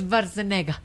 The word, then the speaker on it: Schwarzenegger: Pero amiguito, mire, es Schwarzenegger. – Es Schwarzenegger. Para nosotros nos enseñaron. Es Schwarzenegger. – Ah, sí. Pero entonces todos los doblajes de las películas se han hecho mal. – Mal, mal pronunciados.